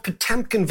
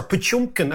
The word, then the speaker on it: Potemkin: And it's called Potemkin villages, or Potyomkin, as the Russians would pronounce it, villages.